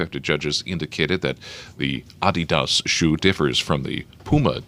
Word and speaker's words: Adidas: And in Germany, Adidas, or if you prefer the European pronunciation, Adidas, has dodged a legal threat to one of its best-selling tennis shoes.